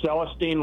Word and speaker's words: Celestine: Okay, it's Celestine, like Celestine Prophecy. – Thank you very much. I feel embarrassed that I mispronounced the end of it.